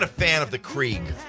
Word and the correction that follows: Keurig: It's pronounced Keurig.